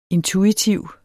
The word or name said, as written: intuitiv